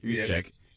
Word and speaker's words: Juszczyk: Juszczyk. Yes. Juszczyk.